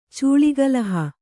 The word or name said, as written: ಚೂಳಿಗಲಹ